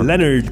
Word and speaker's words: Leonard: Ah, on dit-tu Leonard ou Leonard? – Leonard Cohen. – Leonard. – C'est ça, on prononce pas le O.